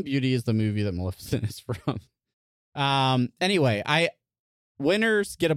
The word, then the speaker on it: Maleficent: But it's got the dragon from that one movie about the dragon, Maleficent.